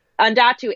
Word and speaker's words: Andatu: His name is Undatu, A-N-D-A-T-U.